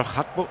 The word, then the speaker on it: Gakpo: Si dice Oliver Hakpo, es que se debe pronunciar así. Yo le voy a seguir llamando Gakpo porque me sale un poco.